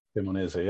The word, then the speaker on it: Cremonese: So, excuse my pronunciation on it. I'm Cremonese. Is that right? – Cremonese, yeah.